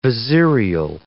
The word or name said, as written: vizierial